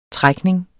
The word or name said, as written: trækning